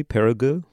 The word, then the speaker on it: Périgueux: The saints' bodies were joined with their heads last week at the artisans' workshop in, pronounce it for me, Périgueux. – Yeah, that's perfect.